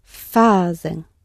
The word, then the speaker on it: fazem: And where's your stress? We're going to go down on the penultimate syllable because we're in the present tense. – Fazem.